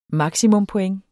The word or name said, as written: maksimumpoint